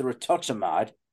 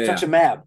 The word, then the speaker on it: rituximab: Okay, getting back into football shape and whatnot with the rituximab. Did I say that right? – Rituximab, yeah. Yeah, rituximab. Yeah. It's rituximab.